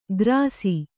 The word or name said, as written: ಖರ್ಸಿ